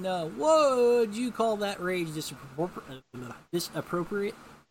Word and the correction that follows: disproportionate: Is that the right way to say it? Okay, I'm having problems here. – Disproportionate. – Disproportionate. Oh, that's right.